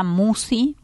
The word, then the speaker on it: Musci: Que lleva mi nombre, Marisa Musi. Musi se escribe M-U-S-C-I.